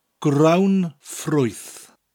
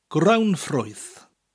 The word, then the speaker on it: grawnffrwyth: It is 12 letters long and is pronounced grawnffrwyth. Grawnffrwyth. I'll slow it down a bit. Grawnffrwyth. Grawnffrwyth. The first part of the word grawn actually sounds like an English word, ground, spelt G-R-O-U-N-D, but without the D sound.